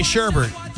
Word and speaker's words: sherbet: Dad has his son say sherbet. The son says, McKenzie? – Sherbet. – Which is how you say that. – Which is how one should say it, because it doesn't have a second R.